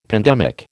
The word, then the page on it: pandemic: American English